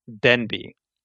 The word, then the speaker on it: Denby: Is it General Genby? – Denby. – Spell that. – D-E-N-B-Y. – D-E-N-B-Y. Yeah. Okay.